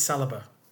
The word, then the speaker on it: Saliba: Of course it's Saliba.